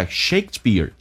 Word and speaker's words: Shakespeare: 那么我们看一下 Shakespeare 叫做莎士比亚Shakespeare, 这个 P 要进行浊化，可用不用发音 Shakespeare.